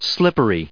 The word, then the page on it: slippery: adjective